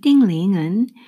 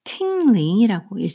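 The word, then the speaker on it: 띵링은 (丁玲): 이 띵링은 영어권에서는 어, 팅링이라고 읽습니다. 팅. 그래서 어, 중국어로는 띵링이지만 영어에서는 팅링으로 하셔야지 찾으실 수가 있어요.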